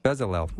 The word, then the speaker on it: Bezalel: Bezalel.